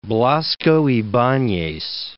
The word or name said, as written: Blasco Ibanez